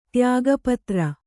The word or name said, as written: ತ್ಯಾಗಪತ್ರ